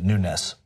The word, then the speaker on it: Nunes: I'm going to tell you, he doesn't even pronounce it Nunes. He pronounces it Nunes. He doesn't want anything to do with that. Really, he's Nunes.